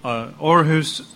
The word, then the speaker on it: Aarhus: Aarhus is a town in Denmark. It's spelled with two A, but it pronounced in Danish O.